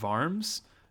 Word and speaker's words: Worms: And so basically Luther was called to appear before, I'm going to say the Diet of Worms, because, you know, Wittenberg, even though it's spelled with a W, it's, you know, German, so you say it with a V.